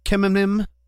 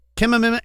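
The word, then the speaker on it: Khmeimim: The latest and most unusual of the incidents saw 10 unmanned aerial vehicles, or UAVs, packed with explosives, descend upon, I don't know if I'm pronouncing this right, but Kemenim. – You're not. – Kemenim Air Base, which is Russia's vast military headquarters in northwestern Latakia province.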